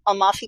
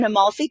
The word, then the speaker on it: Amalfi: Napoli and an Amalfi Coast. Kristen, is that how you say it? – That's how I would say it.